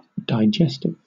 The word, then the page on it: digestive: British English